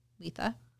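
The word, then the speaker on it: Litha: Depends where you are in the world, how you say it. I call it Letha.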